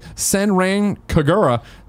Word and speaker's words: Senran Kagura: Senran Kagura series is making its debut on Switch with Senran Kagura.